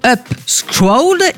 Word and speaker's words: Upscrolled: Son nom donc se prononce Upscrolled et son ambition est claire le dude, proposer une nouvelle façon de découvrir du contenu en ligne.